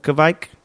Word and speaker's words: kveik: Kveik, yeah. It's a Norwegian, well, I guess it's Norwegian, though I don't know. Maybe it's Swedish as well, but it's spelled K-V-E-I-K. It's pronounced Kveik, and it is, just a huge range of yeast.